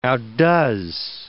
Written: outdoes

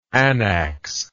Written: anax

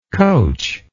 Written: coachability

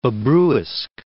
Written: Babruysk

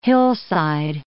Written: hillside

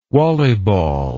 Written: wallyball

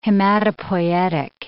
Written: hematopoietic

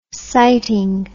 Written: sighting

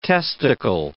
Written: testicle

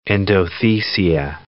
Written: endothecia

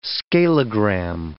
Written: scalogram

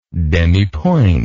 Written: demipointe

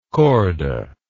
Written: korda

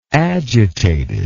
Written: agitatedly

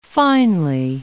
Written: finely

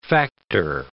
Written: factor

